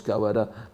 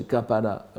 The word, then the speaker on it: kawara: maintenant ça se prononce kawara, mais ça se prononçait kapara à l'époque ancienne, kaparake, etc.